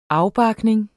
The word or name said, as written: afbarkning